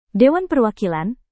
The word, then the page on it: dewan perwakilan: Indonesian